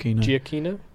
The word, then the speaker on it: Giacchino: Giacchino.